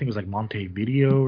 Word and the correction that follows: Montevideo: or something like that. – Montevideo. – Yeah. Much better pronounced by Venom there.